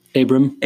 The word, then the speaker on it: Abram: Abram.